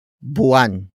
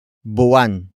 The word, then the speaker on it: buwan: It is an object marker that introduces what I am drawing for Isaac, and that is buwan. Buwan. Buwan. Buwan is spelled as B-U-W-A-N.